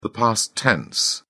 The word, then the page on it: past tense: British English